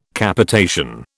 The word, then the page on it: Capitation: British English